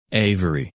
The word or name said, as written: Avery